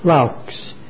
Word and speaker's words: Laux: You should have received Zox pages from, I believe the name is pronounced Laux, L-A-U-X.